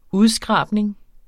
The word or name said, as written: udskrabning